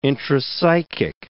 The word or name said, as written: intrapsychic